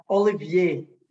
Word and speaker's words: Olivier: I know we chatted about this a little bit beforehand, but one of my boys is named Oliver. So it's natural for me to say Oliver, you know, which I think, Americans would pronounce maybe your name that way. But in France, it's is it Olivier, right?